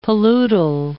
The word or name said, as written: paludal